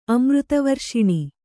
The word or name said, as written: ಅಮೃತವರ್ಷಿಣಿ